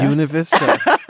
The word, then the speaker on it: Buena Vista: And you know that because I pronounce it Buena Vista. – Buena Vista. I've been told that's the proper pronunciation. – Well, it should sound like beautiful.